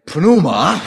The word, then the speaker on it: pneuma: Now, I can say that a little better, but that's still difficult for us in English because it begins with the two consonants PN, and that's a little bit difficult for English-speaking people to pronounce. But nevertheless, it's the same phenomenon. You can't say pneuma without breathing.